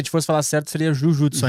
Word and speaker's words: jujutsu: Então é jujutsu, é o termo correto, né? Aí por uma questão de pronúncia,